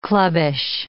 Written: clubbish

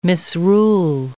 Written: misrule

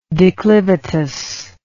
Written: declivitously

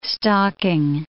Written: stocking

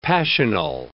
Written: passional

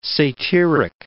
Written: satyric

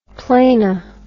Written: plani

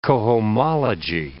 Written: cohomology

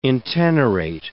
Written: intenerate

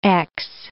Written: ex

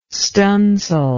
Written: stunsail

